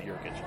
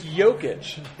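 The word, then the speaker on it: Jokić: It's but it's Jokic. – No, it's okay. It all emanates from Sandy Clough mispronouncing Nikola Jokic's name.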